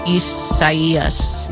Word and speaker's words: Isaias: Well, no, but it's probably the Spanish rendering of it, which if that's the case, then the I is pronounced like an E. – Isaias.